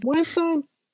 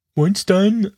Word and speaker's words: Weinstein: Ja, äh, produziert von der äh, Wein Weinstein Company, das muss man ja offensichtlich Englisch aussprechen. Weinstein. Ja. Weinstein.